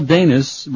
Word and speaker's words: Danis: Miguel Danis, rhymes with anus, played the bass.